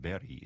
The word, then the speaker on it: Berg: We are going to hear some music now by a Swedish composer named Natanael, and his last name looks like Berg. It's pronounced Berry in Swedish.